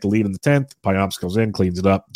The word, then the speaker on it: Payamps: Joel Payamps is how I pronounce it, and that's how I looked it up, and that's the closest thing I can find is Payamps.